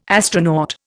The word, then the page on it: astronaut: Indian